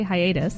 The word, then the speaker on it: hiatus: After only 2 months, hiatus, or hiatus, or how do you pronounce that word? – I would say hiatus.